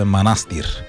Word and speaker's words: Манастир: Аз в четвъртък казах, че селото е манастир. Веднага Михаил Делирадев ме контрира с: абе, знаеш ли, че ударението е манастир? – Ами това е като винарска изба Драгомир.